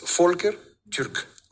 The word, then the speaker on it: Volker Türk: Ah, bueno, es que es austriaco, entonces la V en alemán se pronuncia como F, entonces Volker, okay. – Volker, y la U con diéresis, no suena U, sino U, que es un sonido difícil de pronunciar en español, entonces Volker Türk.